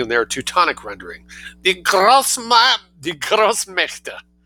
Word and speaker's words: Großmächte: So, Großmacht, great power, Großmächte, A umlaut, but the same spelling, M-A with an umlaut, C-H-T. Großmächte, die Großmächte, D for plural, D-I-E, plural, German, D.